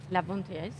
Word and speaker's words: Lars von Trier: De Lars von Trier, ¿se dice así? – Lars von Trier, sí.